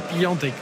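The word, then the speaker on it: Piątek: Et donc Piatek, ça se prononce aussi Piantek.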